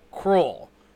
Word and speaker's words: Krull: It's a movie called Krull. K-R-U-L-L.